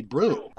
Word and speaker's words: Brougham: I'm convinced it's broom. I mean, it should be correctly broom. – It is broom.